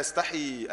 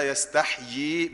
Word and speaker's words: يستحيي: كتير من الناس بيفكر ان الله لا يستحي ان يضربه، غلط. ان الله لا يستحيي بيئين، كما قال الشيخ رحمه الله، هي اللغه الفصحى، اللغه الفصيحه، ان انت تنطق يئين في ايه؟ يستحيي.